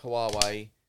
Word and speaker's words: Huawei: So he was involved in the discussions with Huawei, if that's even how you pronounce it.